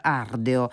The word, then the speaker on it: Ardeo: Quatuor Ardeo, se lo vogliamo pronunciare alla latina, visto che il nome di questo quartetto, che è stato creato a Parigi ormai da diversi anni, vuole proprio riportarci al senso ardente della passione musicale.